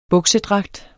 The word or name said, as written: buksedragt